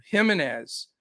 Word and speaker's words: Jimenez: But Vaughn Abreu, Jimenez, that's with an H.